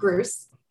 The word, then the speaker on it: Gruss: You can find me by my name, Heidi Gruss. It rhymes with Seuss, but it's spelled differently. So it's G-R-U-S-S.